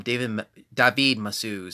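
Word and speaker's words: David Mazouz: David Massue.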